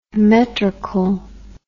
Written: Metrecal

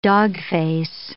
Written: dogface

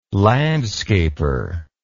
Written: landscaper

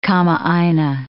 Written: kamaaina

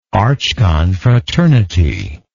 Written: archconfraternity

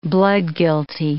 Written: bloodguilty

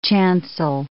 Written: chancel

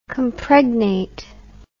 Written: compregnate

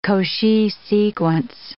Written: Cauchy sequence

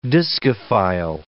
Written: discophile

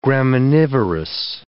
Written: graminivorous

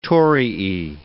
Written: torii

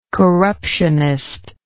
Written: corruptionist

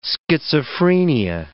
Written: schizophrenia

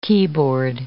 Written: keyboard

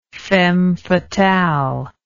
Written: femme fatale

